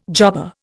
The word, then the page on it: jobber: Indian